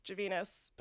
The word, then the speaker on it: Gavenas: And my uncle says Gavenas, but my dad was like, I want a name that rhymes with anus.